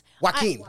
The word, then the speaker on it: Joaquin: I do think, and I always say his name wrong, but Joaquin Phoenix? – Joaquin Phoenix.